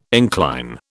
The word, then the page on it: incline: British English